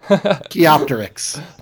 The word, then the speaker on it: Archaeopteryx: I'll never be able to pronounce the name of that dinosaur, but Archaeopteryx. – Archaeopteryx.